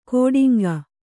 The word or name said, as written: ಗೋಟಿಂಗ